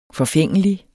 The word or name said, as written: forfængelig